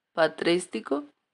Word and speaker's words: patrístico: En el gótico, que este es del 1250, y fin de la Edad Media y 1453 fines en el periodo pretástico, lo siento, patrístico, Disculpen si no los pronuncio un poco bien.